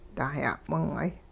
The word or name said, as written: dah-ya'wing'ay